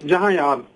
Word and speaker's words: Jahanyab: Yes, it's Jahanyab, to be exact, actually.